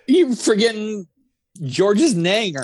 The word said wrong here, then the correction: Niang: George Niang.